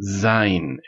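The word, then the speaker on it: Zain (ז): y es muy importante recalcar que el sonido de esta letra es Z, como un zumbido, Zain.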